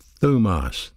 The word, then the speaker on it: thumos: Wrath is another Greek word pronounced thumos, T-H-U-M-O-S, thumos.